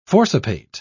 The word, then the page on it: forcipate: British English